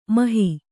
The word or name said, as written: ಮೋಹ